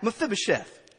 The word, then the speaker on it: Mephibosheth: Mephibosheth.